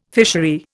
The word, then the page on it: fishery: Indian